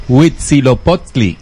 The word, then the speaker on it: Huitzilopochtli: Este señor se llamó Huitzilopochtli. – ¡Uy, lo dijiste bien!